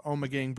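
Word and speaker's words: Ommegang: So every once in a while, Game of Thrones comes out with a new beer from this brewery called Omegang Brewery, and we're actually say that name again.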